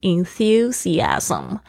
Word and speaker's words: enthusiasm: All right，好，这边的那个重音是在 thu 上面，特别要注意一下你们 th 的发音哦，要记得轻轻的咬住舌尖。 enthusiasm，好的，接下来是讲到这个 compassionate，他说的这个占星术呢，真的是帮助他变得更理解人，more understanding，more compassionate，这个是我非常喜欢的一个单词，就是有同情心的 compassion，就是同情心，compassionate。